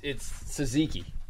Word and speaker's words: tzatziki: It's tzatziki.